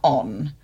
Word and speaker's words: ohne: Let's talk a little bit more about OHNE. And where does the name come from? – It's a German word, not pronounced on, pronounced ohne, meaning without.